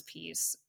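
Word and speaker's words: P1ECE: It's like P1 ECE, and you just, but you pronounce it as peace.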